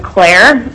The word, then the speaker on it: Cler: My name is Jessica Claire. My last name is spelled C-L-E-R,